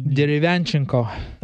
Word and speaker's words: Derevyanchenko: Sergey Derevyanchenko.